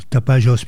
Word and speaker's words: Tapajós: Et oui, Tapajós, puisque le J ne se prononce pas Jota au Brésil.